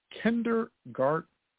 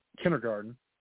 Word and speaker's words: kindergarten: National Kindergarten Day. Kindergarten. I think we all pronounce it. It's like one of those words that we pronounce wrong. Everybody usually will say kindergarten and forget the D.